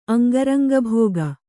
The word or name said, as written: ಅಂಗರಂಗಭೋಗ